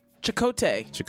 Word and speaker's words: Chakotay: So they said, George said, also said, get a henna tattoo, Chakatoy style. I'm not sure if I was saying that right. Chakatoy. Chakatoy. – Chakotay.